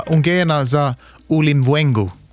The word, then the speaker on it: Ungena za Ulimwengu: Jag kan försöka. Ungena za Ulimwengu. Lät det bra? – Det lät jättebra.